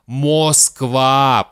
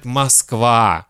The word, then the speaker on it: Москва: Поэтому мы не хотим безударную «о» произносить как «о». Мы произносим короткое «а». Мы не говорим «Москва», потому что мы ленивые, это очень трудно. Легче сказать «Москва». Но это, конечно, шутка.